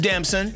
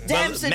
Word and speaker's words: Damson: So from turtle to Nunu, word is Lori Harvey is now dating the star of Snowfall, handsome British actor Damson Idris. Did I say it right here, crack? Is it Damson or Damson? – Damson. – Damson.